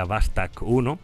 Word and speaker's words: Vostok: Allí nos encontramos con la imponente nave de la misión, la Vostok 1, que en ruso en realidad se pronunciaría Vastok 1,